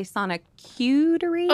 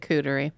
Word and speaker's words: Sonicuterie: I want to be sure, Sharks, that we call it a Sonicutery. – Oh, I'm so sorry. Did I say it wrong? – Mm-hmm. – Oh, Sonicutery. – You said cootery.